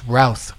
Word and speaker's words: Routh: I think it's pronounced Routh.